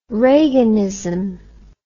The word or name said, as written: reaganism